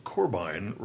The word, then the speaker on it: Corbijn: The Dutch IJ sounds like an English long I, so Corbijn rhymes with Gorwine.